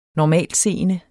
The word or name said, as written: normaltseende